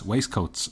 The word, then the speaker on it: waistcoats: It's the double S, waistcoats, but I don't pronounce the middle T.